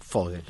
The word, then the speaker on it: Vogel: que se escriben con B corta Vogel, no Vogel, pero se pronuncia Vogel.